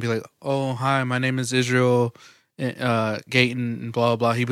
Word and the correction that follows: Gaitan: No, say it right. Israel Gaitan. There you go.